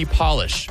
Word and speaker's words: polish: There is one word in the English language that changes its meaning and pronunciation whenever you capitalize it. What is that? Polish. Capital P, Polish, lowercase p, Polish. Changes everything.